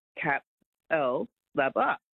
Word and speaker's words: Capalaba: And it took me ages to learn how to say Capalaba. – Oh, well, it might not be my show you're enjoying then. – Because that's how, if you look at it, it's Capalaba. Capalaba.